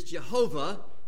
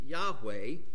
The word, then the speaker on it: Yahweh: Now, I'm sure all of your translations have "Lord" here in capital letters, capital L, and then smaller caps, many times O-R-D. The Hebrew behind this particular name for God, the old pronunciation is Jehovah. Sort of the modern way this is pronounced is Yahweh.